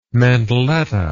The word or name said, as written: mantelletta